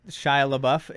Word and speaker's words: Shia LaBeouf: Shia LaBeouf in the yeah yeah.